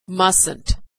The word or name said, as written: mustnt